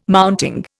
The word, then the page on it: mounting: Indian